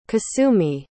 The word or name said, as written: kosumi